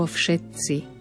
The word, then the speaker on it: všetci: Všimneme si v druhom riadku slovo všetci. Opäť upozorňujem na to, niekoľkokrát som to už povedala. Dodržme výslovnosť, správnu výslovnosť tohto slova. Všetci. Ďalej, v ďalšom odseku, dvakrát sa nám opakuje spojenie M na konci slova a M na začiatku slova.